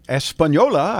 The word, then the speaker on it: Española: Española, I know how to say that.